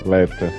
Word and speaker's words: Leta: É Leda, Lida? Como é que fala? Lestrange lá, esqueci o nome. – Ah, é. Leta.